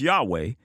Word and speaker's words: Yahweh: They asserted that the God of Israel's name should be pronounced Yahweh, because to them, he was nothing more than an offshoot of the pagan deity Yahoh.